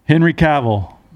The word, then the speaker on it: Cavill: Henry Cavill. Hot guy. Is that how you say it? – Yeah.